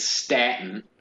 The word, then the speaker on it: Staten: It, it's statin. Yeah, Staten I, Island.